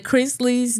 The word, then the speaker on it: Chrisley: Last but not least, the Chrisleys knows best. Am I saying that right? Because I don't watch the show. – Chrisley? Yeah. Chrisley.